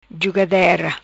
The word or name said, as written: Jugadera